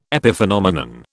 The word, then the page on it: epiphenomenon: British English